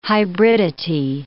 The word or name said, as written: hybridity